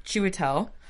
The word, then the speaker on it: Chiwetel: He's terrified, and Chiwetel. Did I say that right? – Yeah.